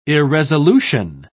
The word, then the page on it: irresolution: British English